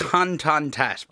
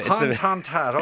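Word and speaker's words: Hoan Ton-That: Is that the correct pronunciation? – I think it's Hoan Ton Thatt, but it's a bit of a... – Hoan Ton Thatt, okay.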